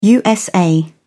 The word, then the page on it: usa: British English